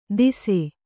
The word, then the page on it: ದಿಸೆ: dise